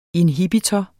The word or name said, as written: Inhibitor